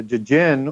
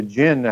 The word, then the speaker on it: Jinn: and and the Jedi is is a play on the Jin or the Jinn, as it should be pronounced, out of the Quran.